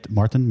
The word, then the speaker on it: Marten: Toen we net, ehm, voordat we begonnen, was ik me opeens ook heel erg bewust van jij, Martin, met M-A-R-T-E-N. Dat ik opeens. – heel erg ging nadenken. Ik moet het uitspreken met een duidelijke E en niet dat het.